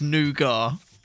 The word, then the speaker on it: nougat: Are you sure it's nougat? – Yes. – Is it really? – Yes. I mean, there is a T on it, but you don't pronounce it.